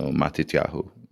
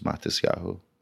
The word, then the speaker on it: Matisyahu: So Matityahu. But the Ashkenazi pronunciation is Matisyahu.